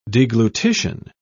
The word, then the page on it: deglutition: British English